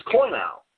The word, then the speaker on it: koinou: And the verbal form of this word is koinou, K-O-I-N, short O, long O, Strong's number 2840, which appears in verse 15, the next verse that we are going to present.